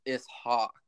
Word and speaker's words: Ishak: So to anyone wondering, my name is, so my name in Arabic is pronounced Ishak.